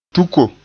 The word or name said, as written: tuko